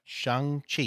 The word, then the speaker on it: Shang-Chi: All right, so the movies that were in Phase 4 consist of Black Widow, Thor, Love and Thunder, Eternals, Shang-Chi. – That's right. – Did I say that right? – Shang-Chi, yes.